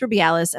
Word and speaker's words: Rubiales: Luis Rubiales, am I saying that right? – I mean, yeah. – Without an accent? Okay. I mean, you can lisp if you want okay.